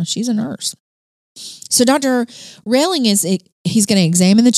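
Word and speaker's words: Rehling: So, Dr. C.J. Relling, I think is how you say this. It's R-E-H-L-I-N-G. Relling. – Sounds right. – Relling.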